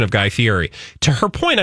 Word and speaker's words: Fieri: She said, could you please pronounce Guy Fieri's name correctly? He pronounces it Fieri, not Fieri.